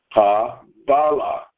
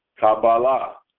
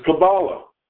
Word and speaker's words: Kabbalah: Because in Hebrew, the accent is on the second syllable, Kabbalah, Kabbalah. Another way of pronouncing it, the same word in English, is Kabbalah, because the accent is on the first verse.